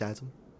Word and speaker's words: Shazam: Actually, it's pronounced Shazam.